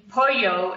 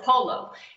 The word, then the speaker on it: pollo: You mean pollo is how you say chicken, and he calls it polo. So. – Because it's spelled P-O-L-L-O. And he says polo.